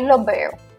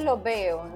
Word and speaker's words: los veo: But in Puerto Rico, sometimes the last S isn't pronounced really strong, is it? – Exacto. Entonces lo veo, ¿no? – Lo veo.